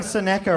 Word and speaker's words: Seneca: Am I saying Seneca right? – Nailed it.